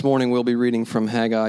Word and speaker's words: Haggai: Bibles to the prophet Haggai, whose name pronunciation is, of course, infamous. Michael and I had a discussion this week about how to pronounce his name, but we're going to go with Haggai this morning.